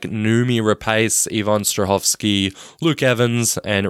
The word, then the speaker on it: Noomi Rapace: Noomi Rapace.